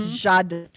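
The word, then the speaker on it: Giada: The former Food Network female chef, is it Gianna? – Yep, Jada. Mm-hmm. It's Jada. She pronounces it Jada.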